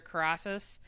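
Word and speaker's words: Karras: who played Father Carassus in The Exorcist. Am I saying that right? Carassus? – No, Carass. – Carass.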